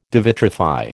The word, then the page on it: devitrify: American English